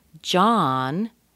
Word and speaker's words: John: And John, be careful on this one. You see the O, but it's a short O. It's pronounced as ah, as in father.